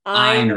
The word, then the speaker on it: Ayn: Ayn Rand. Yes. – Okay. Thank you. – She did it to sound like I.